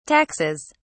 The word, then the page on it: taxes: British English